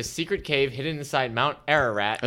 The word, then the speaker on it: Ararat: A rat. Nope. I said it wrong. A ra-rat. Oh, boy. A-R-A.